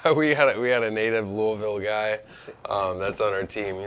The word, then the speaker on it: Louisville: He's like, no, you're saying it wrong. You gotta say it like you got stuff in your mouth. – Louisville. – Yeah. I don't know. – The less you move your lips, the more like Louisville.